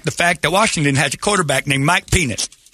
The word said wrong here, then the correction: Penix: Penix. – Is that how it's pronounced? I've been saying it wrong the entire time. I thought it was plural penis.